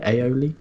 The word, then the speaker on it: aioli: Have you heard of it, aioli? – Yeah.